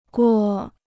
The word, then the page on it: 锅: guō